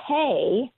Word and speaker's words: pey: And so when you look at 80, it is the Hebrew letter pay. – And spell and that, so, is it P-A-Y, yeah, or how do you spell that? – It's P-E-Y. – Oh. – P-E-Y, that's a good question, right?